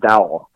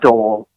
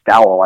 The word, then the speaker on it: Dowell: And the coordinator, now, his first name is pronounced Dowell. It's not Dowell Logans, but I guess it's Dowell, I heard on a video last night.